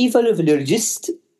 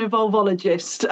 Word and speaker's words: evolvologist: I know I've said that all wrong. You tell the listeners how you pronounce that. – Well, I like to call myself an evolvologist, although the business name is Evolvology, but that was good.